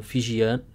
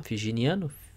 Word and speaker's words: fijiano: Então ele é um fijiano. Uh, como é que se pronuncia? Figiniano? Fijiano.